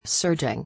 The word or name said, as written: surging